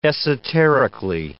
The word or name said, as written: esoterically